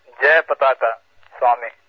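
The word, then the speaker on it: Jayapataka Swami: Yes, and by the way, Joe, I think his name should be pronounced as Jay Pataka Swami.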